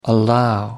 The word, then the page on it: allow: American English